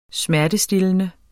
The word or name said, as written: smertestillende